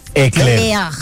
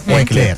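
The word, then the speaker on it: éclair: Ay, y vale, voy a decir que siempre dudo en cómo pronunciar el eclair. ¿Es que se dice? – Eclair. – Eclair. – Es eclair. El eclair, el que viene relleno de cremita de chantilly.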